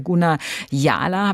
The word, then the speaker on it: Guna Yala: Beispiel auf einer Inselgruppe zwischen Kolumbien und Panama, da lebt das Naturvolk der Guna Yala, habe ich in Ihrem Buch gelesen.